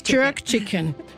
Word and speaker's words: Jerk Chicken: Jerk Chicken.